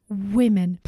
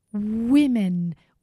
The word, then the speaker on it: women: Please pay attention to my pronunciation here. Men, men, more than one, plural, men, and then women, women, more than one, plural. Careful, not women's or women's, no, women. Listen, one woman, many women.